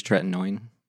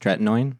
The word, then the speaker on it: tretinoin: Yeah, I use tretinoin. – Use what? – It's pronounced tretinoin. – Oh. – Tretinoin.